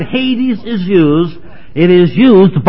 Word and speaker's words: Hades: It is spelled H-A-D-E-S, Hades.